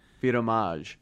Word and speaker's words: Vidmasce: Vidmasce.